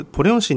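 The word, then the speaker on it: ポ リョン (보령): はい、日本のお隣の国、大韓民国中西南道にありますポリョン市になります。こちらポリョン市につきましては、読み方が大変難しいので、漢字で書きますと、ポリョンのポはですね、保険の方になりまして、リョンはですね、丁寧のねと書きます。ぜひ覚えていただければと思います。 – はい、ございます。確かにポリョン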